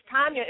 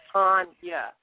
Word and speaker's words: Tonya: man, when people call me Tanya, I'm like, it's Tanya. It's Russian, so it's the long A.